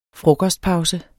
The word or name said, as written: frokostpause